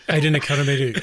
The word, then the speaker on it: Nicodemu: Ada Nicodemu. I thought I'd given her one extra syllable, but it's two.